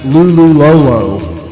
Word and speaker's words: Lululolo: So the title of the band, or the name of the band, it looks like to read it LLRR, but according to this Bandcamp page for the record, it's pronounced Lululolo.